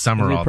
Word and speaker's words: Sumrall: The Florida football coach, John Sumrall. Is that how you say it? He's new. He became part of what's S-U-M-R-A-L-L. – I know, it's weird. It is Summerall, though. – Is it pronounced Summerall? – Yeah. – I feel like there's a letter missing. – It is. It's not spelled right, but that's how it's pronounced.